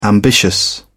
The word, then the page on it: ambitious: British English